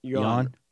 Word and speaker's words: yuan: Yuan.